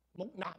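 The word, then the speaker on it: 木讷: 国语叫 Moon 啊，广东话是什么？木纳啊，是纳字啊，木纳啊。哦，OK，我查一个广东话字典，佢嘅发音叫木纳哦。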